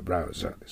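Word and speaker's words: browser: 日本語ではブラウザーと言ってますけど、発音は R がありますからブラウザーです。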